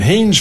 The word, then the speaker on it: Hains: And the GoFundMe page, you type in Art Hains, and Hains, folks, is spelled like Rains, except that it's an H instead of an R. H-A-I-N-S.